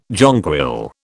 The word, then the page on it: jonquil: British English